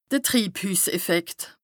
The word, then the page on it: de Triibhüsseffekt: Bas-Rhin; Strasbourg